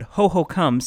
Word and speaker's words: Hohokam: Now, I have heard several ways to pronounce Hohokam, and everyone seems to have their preferred way, but Hohokam seems to be the pronunciation of those who studied this ancient civilization, so that is what I'm going to go with.